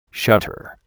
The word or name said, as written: shutter